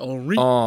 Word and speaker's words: Henri: Henri, is that how you pronounce it? Henri. – He was very French. Very French. Henri.